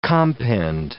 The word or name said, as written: compend